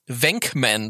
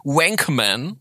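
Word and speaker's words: Venkman: Es heißt wirklich Wenkman. Das ist nicht äh, verkehrt deutsch ausgesprochen. Viele sagen Wankman.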